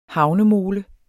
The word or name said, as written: havnemole